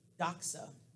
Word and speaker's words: doxa: And the word there, the Greek word is doxa, and it's spelled D-O-X-A.